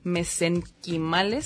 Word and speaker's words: mesenquimales: Bueno, ella estudió química farmacobióloga en UDG y bueno, tiene ya 7 años trabajando con este tema de células madres mesenquimales, ¿sí lo dije bien, Corina? – Sí, es correcto.